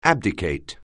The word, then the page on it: abdicate: anglais